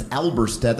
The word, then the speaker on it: Alberstadt: My last name is Alberstadt. That's a German name. There's a silent D in there. That's what freaks people out when they see it for the first time.